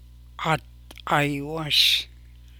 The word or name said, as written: Gad Gaaywáas